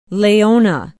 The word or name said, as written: laona